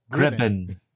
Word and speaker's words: Gribben: I want to thank our special guest, Toby Grillin. Am I saying that right? – Gribben.